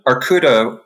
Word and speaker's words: Arkouda: Arcuda, which is the Greek word for bear, it's spelled A-R-K-O-U-D-A, is an open source framework for big data, and it's available from GitHub, so anyone can check it out.